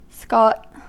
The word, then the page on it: scot: American English